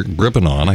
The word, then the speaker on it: Gribbenaw: Well, because it's not the way the Germans would pronounce it, right? You know, but good Americans, it's Pastor Gribbenaw. I told him it's like a crib with a baby in it. And you go, aw, Gribbenaw, Gribbenaw. Right, I actually had to use it.